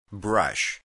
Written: brush

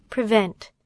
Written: prevent

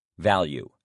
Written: value

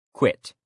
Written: quit

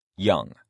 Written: young

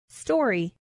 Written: story

